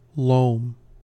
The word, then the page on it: loam: American English